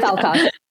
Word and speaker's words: Falkoff: Am I pronouncing Falkoff right? – I like to pronounce it as close to a swear as possible because it's funny. But yeah, Falkoff.